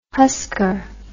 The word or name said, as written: husker